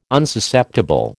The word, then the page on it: Unsusceptible: American English